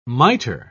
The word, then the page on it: miter: British English